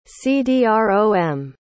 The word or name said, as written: cdrom